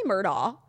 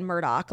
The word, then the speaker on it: Murdaugh: Okay, so it's spelled M-U-R-D-O-U-G-H. – Oh, so it's technically like, Murdaugh. – I get it. – It's technically Murdaugh. Everyone in the documentary said Murdaugh, like literally Richard, Rupert, whatever. – I just did the same thing. – Rupert's family, but it's spelled differently and it's Murdaugh.